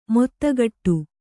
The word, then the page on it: ಮೊತ್ತಗಟ್ಟು: mottagaṭṭu